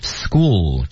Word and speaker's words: school: 那么这句话呢，对于大家来说呢，要注意点 school 这个单词要把尾音要留住，因为很多人读这个词的时候会把 school 就读成这样子是不行的。 – 嗯， school 应该有一个舌尖抵上齿背的过程，把这个留住。